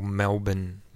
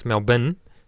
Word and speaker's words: Melbourne: Now, I just wanted to talk about the whole Melbourne thing, because this is kind of big, because Australians insist that it's Melbourne, not Melbourne. However, I can remember there is a Melbourne comedian slash actor whose name is Shane Bourne.